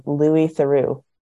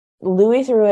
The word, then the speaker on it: Theroux: So are you familiar with the BBC documentarian Louis Theroux? – No.